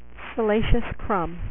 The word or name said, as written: Salacious Crumb